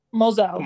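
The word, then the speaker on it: Mozelle: It is a really dark film, and I did get like horror vibes, like when how you say her name? Moselle? – Moselle.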